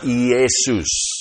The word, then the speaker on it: Iesus: In the Greek, the name is pronounced Iesus.